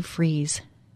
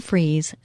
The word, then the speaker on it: Fries: I want to invite you to the Sue Freeze website. It's Sue Freeze, spelled like fries, For those of you that can see on Facebook Live or YouTube, you can see the way the name is spelled.